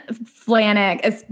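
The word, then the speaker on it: Flajnik: Ben Flagnerge. – Ben Flanick, but it's spelled with a J.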